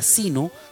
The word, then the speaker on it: Zeno: Y también estamos en la aplicación Seno, se pronuncia Sino, que se escribe con Z-E-N-O.